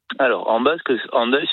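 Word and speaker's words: Hendaye: Et d'ailleurs, Andaille s'écrit aujourd'hui H-E-N, donc peut-être qu'en français on dirait plus facilement Andaille. – Ah oui. – Mais c'est bien Andaille qu'on dit. On a bien conservé le son ou la prononciation qui était celle de l'orthographe du XVIe siècle.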